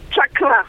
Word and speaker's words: chakra: Well, I'll tell you which is the correct pronunciation. It's chakra.